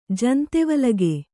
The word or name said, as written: ಜಂತೆವಲಗೆ